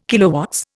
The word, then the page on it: kW: Indian